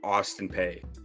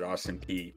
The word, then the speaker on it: Austin Peay: I mispronounced it Austin P, but I like the way I said it better.